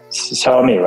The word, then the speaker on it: Xiaomi: Xiaomi, right? Did I say it correctly? – Yeah, that's correct.